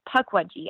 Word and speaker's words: Pukwudgie: And, some of them, and, and I just realized I pronounced his his name wrong. It's Pukwudgie. I always say Pukwudgie because um, of the Irish puka for some reason.